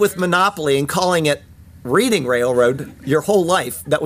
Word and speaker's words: Reading: And Reading. I've said Reading. I know that it's Reading. And I convinced myself that I was going to say it correctly, and then I said the opposite. It is Reading, Pennsylvania, like the Reading Railroad on the Monopoly set.